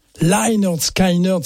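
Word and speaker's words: Lynyrd Skynyrd: Si vous aimez Lienerskinorn, Lienerskinorn, c'est comme ça qu'il faut le prononcer. Je fais toujours l'erreur.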